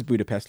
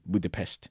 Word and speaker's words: Budapest: back and forth over the pronunciation of Budapest. He says Budapest, like, you know, 95% of people on Earth. She's being hoity-toity, being Budapest.